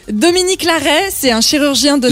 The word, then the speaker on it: Larrey: L'arrêt, mais ça s'écrit pas comme l'arrêt. – Larrey. L-A-R-R-E-Y.